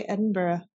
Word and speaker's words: Edinburgh: You gotta say Edinburgh. That's how it's said. I'll say Edinburgh. – Yeah, it's Edinburgh.